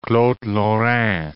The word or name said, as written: Claude Lorrain